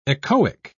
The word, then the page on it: echoic: British English